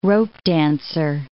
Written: ropedancer

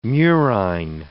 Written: murine

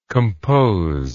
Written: composable

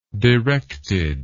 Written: directedness

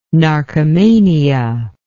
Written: narcomania

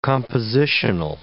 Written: compositional

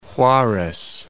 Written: Juarez

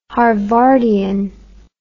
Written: harvardian

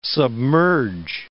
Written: submerge

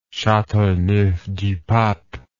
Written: Chateauneuf du Pape

